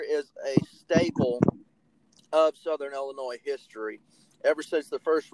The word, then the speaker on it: Illinois: Okay, first off, I'm going to give you a round of applause, because you actually pronounced Illinois right this time.